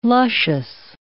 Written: luscious

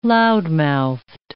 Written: loudmouthed

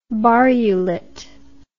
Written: barrulet

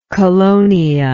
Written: colonia

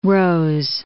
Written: Margaret Rose

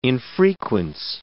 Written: infrequence

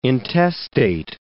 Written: intestate

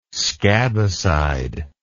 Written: scabicide

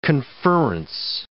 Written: conferrence

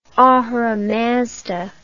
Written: Ahura Mazda

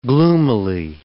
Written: gloomily

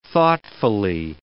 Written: thoughtfully

